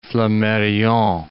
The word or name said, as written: Flammarion